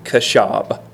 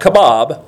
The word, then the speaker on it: qashab: You can spell it Q A S H A B, kashab. Not kebab, but kashab.